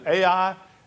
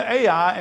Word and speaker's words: Ai: You notice that when Alva read this, he said, apparently that's the right way to say it. I, from my youth, have called it AI, and you just have to live with me saying that wrong if it's wrong.